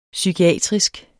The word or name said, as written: psykiatrisk